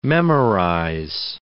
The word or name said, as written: memorize